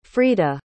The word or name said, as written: frida